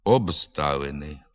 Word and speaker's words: обставини: У префіксах і прийменниках «об», «від», «над», «перед», «під», «між» звуки «б», «д», «ж» перед глухими приголосними зберігають свою дзвінкість. Наприклад, «обставини», «обслуговування», «відправити», «відпустка», «відтовариша», «надпити», «надкручею», «передплата», «перед труднощами», «підписати», «під пахвою», «міжпланетний», «між сусідами».